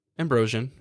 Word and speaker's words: Ambrosian: Am I saying that right? Ambrosian? – Ambrosian, yeah.